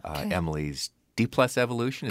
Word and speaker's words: Emily's D+ Evolution: So I want to start out by talking about your new album, Emily's D+ Evolution. Is correct. – That how you say it? – That's perfect.